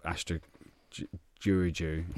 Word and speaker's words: Astra Giurgiu: And you got beat by the Romanian giants of Astra Giurgiu, however you say it. – Very nicely pronounced.